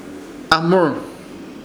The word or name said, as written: AHMUR